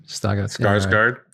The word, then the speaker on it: Skarsgård: Skarsgård, yeah.